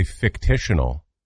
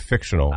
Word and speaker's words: fictional: Fictitional, you add a syllable instead of fictional.